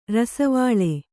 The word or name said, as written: ರಸವಾಳೆ